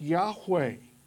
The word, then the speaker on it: Yahweh: As we've mentioned previously, whenever the word Lord occurs in small caps, it's a reference to the covenant name of God, the special name that God gave Israel, sometimes pronounced Yahweh.